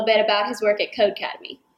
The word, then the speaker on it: Codecademy: Codecademy is the correct pronunciation.